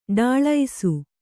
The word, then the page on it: ಡಿಳ್ಳೈಸು: ḍiḷḷaisu